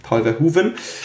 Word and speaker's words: Paul Verhoeven: Manche sagen Paul Verhoeven, aber es wird Hoeven ausgesprochen. Paul Verhoeven.